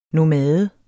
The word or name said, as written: nomade